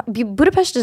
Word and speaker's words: Budapest: Like uh, Budapest is beautiful. I love how you're saying. I, it's apparently the correct pronunciation.